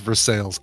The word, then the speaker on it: Versailles: Merrick says, I'm from Pittsburgh and it's pronounced Versailles.